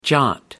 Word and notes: jot: American English

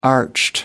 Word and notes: arched: American English

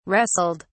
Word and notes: wrestled: British English

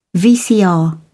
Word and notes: VCR: British English

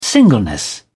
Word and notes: singleness: British English

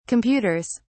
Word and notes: computers: British English

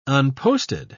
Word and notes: unposted: British English

